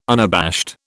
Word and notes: unabashed: British English

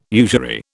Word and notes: usury: British English

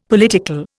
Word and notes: political: Indian

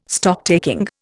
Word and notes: Stocktaking: Indian

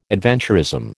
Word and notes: adventurism: American English

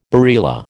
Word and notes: barilla: American English

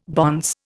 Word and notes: bonce: Indian

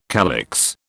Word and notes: calyx: British English

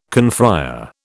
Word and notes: confrÊre: British English

